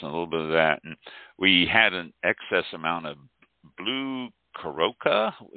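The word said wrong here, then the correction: Curaçao: Is it pronounced? I can't remember. – Blue Curacao, yeah. – Yeah, blue Curacao.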